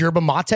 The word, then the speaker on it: yerba mate: Yerba mate? Is that how it's pronounced? – Yeah.